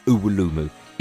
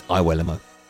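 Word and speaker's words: Iwelumo: And yes, that is how he pronounces his name, not Uwulumu. It's Iwuelamo. I mean, how would you spell it? O-O-O-W-E-L-O-O-O-M-O. Oh, yeah, it could be that, I suppose, but it's not.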